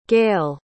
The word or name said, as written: gayle